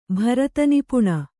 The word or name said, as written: ಭರತನಿಪುಣ